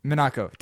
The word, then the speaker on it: Minako: Your pronunciation of some of these names is definitely a little racist. – Sure. How's it pronounced? – Minako, damn it. Yeah, no, that's pretty bad. Now that you say that, I realize how bad Manuki sounds.